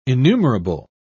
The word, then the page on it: enumerable: British English